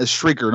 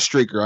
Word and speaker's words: Shrieker: And not a Shrieker, not Streaker. I know it sounded like I said Streaker, not Shrieker.